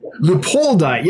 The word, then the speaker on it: leopoldi: With all this talk about angels, do you see quality Lelupoi? How do you say? Isn't it Leopoldi? Wait. Leopoldi, yes, sorry.